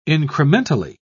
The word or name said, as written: incrementally